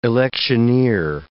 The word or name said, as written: electioneer